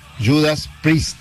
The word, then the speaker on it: Judas Priest: Se pronuncia Judas Priest. Priest. – Judas Priest. No, no, yo dije Priest. – Fuimos dos veces. – Es Priest. Es cierto.